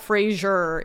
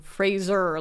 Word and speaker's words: Fraser: It's not Fraser. It's Fraser, like razor.